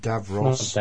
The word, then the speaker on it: Davros: Love and War, Gods and Monsters, The Curse of Davros. Notice I say the correct pronunciation there, American listeners. Davros. – Not Davros. – There's no E at the end of it.